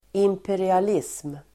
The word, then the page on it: imperialism: [imperial'is:m]